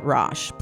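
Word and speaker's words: Raasch: This music is by our wonderful friend, Amy Roche.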